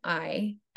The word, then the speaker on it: Marni: So I have a website and my name is spelled Marni.